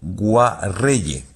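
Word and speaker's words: Kngwarreye: Emily Kame Enguayerre, lo he dicho mal. – Emily Kame. – Con Guarreye. – Emily Kame con Guarreye, sí, sí.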